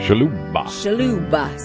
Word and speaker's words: Shalhoub: Shalhoub, 73.